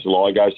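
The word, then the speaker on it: Soligo: Now, you pronounce his name Saligo, is that right? – Yeah, I say Saligo. Some people say Saligo, and Jake's never pulled me up on it.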